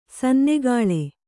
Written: ಸನ್ನೆಗಾಳೆ

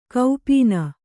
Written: ಗೋಪನ

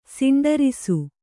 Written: ಸಿಂಡರಿಸು

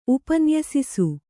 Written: ಉಪನ್ಯಸಿಸು

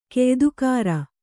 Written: ಕೆಯ್ದುಕಾರ

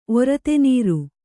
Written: ಒರತೆನೀರು

